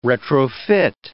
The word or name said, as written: retrofit